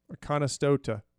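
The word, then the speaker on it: Oconostota: I'm butchering it. What is it? – Let's see. I have it written down right here. It looks like it is Akonostota. – Akonostota. That sounds good.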